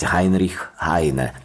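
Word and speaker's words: Heinrich Heine: Вообще-то я предпочитаю произносить его имя и фамилию так, как произносят немцы, и так, как позволяет фонетика украинского языка, то есть Гайнрих Гайне.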